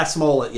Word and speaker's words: Smollett: In February, Jussie Smollett. – Yeah. – Did I say his name correctly? – Yeah, Smollett, yes.